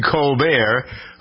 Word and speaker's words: Colbert: Here's Stephen Colbert, whose name is pronounced in English, Colbert, joking about Bernie Sanders.